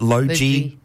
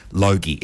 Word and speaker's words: Logie: Or as Destiny's Child pronounced it, Logie. I loved it when they couldn't say Logie. It's a hard G.